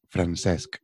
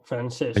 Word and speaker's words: Francesc: Es Francesc. Francesc. Hay una C final. es como decir francés, pero con una C final.